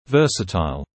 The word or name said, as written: versatile